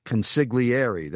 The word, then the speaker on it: consigliere: From a mentee and fellow Brooklynite to my consigliere, that's the way you pronounce it, mafia attorney.